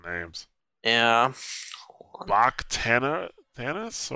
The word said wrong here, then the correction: Bakhtanians: or what was this guy's name? Rob, that's your specialty. There you go. Aris. Aris Bakhtanians.